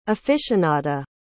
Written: aficionada

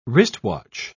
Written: wristwatch

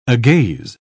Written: agaze

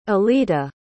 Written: Olita

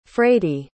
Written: frady

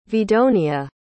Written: vidonia

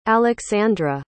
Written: aleksandra

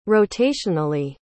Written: rotationally

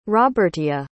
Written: robertia

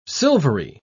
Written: silvery

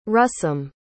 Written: russom

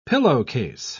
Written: pillowcase

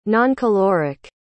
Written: noncaloric

